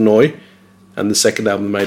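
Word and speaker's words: Nu: Do you know how to pronounce that? It's not new. – Noi. – Noi. This is noi.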